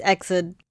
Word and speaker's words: EXID: But I think it's EXID, right? – I would say yes. EXID, yes. – Yes, it's EXID.